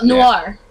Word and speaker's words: Noir: N-O-I-R. – I don't know how to say it. – Oh, Noir. – Yeah, that's how you say it. – Yeah, it's Noir.